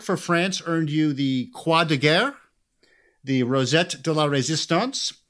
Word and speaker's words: Croix de Guerre: which begs the question, did I pronounce those right? Did that sound? – That sounded good to me.